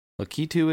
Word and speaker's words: Lakitu: Is that how you pronounce it? Not Lakitu? – I've heard it. It's definitely not Lakitu. I've heard Lakitu. I've heard Lakitu. I believe canonically it's Lakitu. – Lakitu it is.